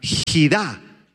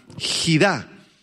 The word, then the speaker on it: jidá: La palabra enigmas es la palabra hebrea jidá, H-I-D-A, con acento en la A, jidá, y se refiere a asuntos difíciles de comprender y oscuros.